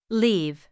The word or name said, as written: leave